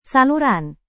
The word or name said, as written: saluran